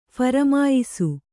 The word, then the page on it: ಫರಮಾಯಿಸು: pharamāyisu